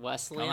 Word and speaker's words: Wesleyan: Wesleyan.